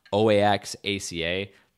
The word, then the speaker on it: Oaxaca: So if you ever see that OAXACA, that is pronounced Oaxaca,